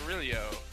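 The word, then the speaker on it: Rillieux: In the South where it's E-U-X? – Oh, he's a Nicholls State redshirt senior. Shane spelled S-C-H-A-N-E. R-I-L-L-I-E-U-X. – Relou. – Shane Relou. – I think so. I think that's how you do it. – Shane Relou.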